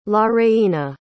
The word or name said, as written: lareina